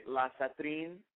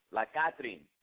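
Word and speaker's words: La Catrina: La Catrine? – Yes, it's La Catrine. Yeah. – La Catrine with a hard C sound.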